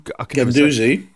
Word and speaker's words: Guendouzi: Guendouzi.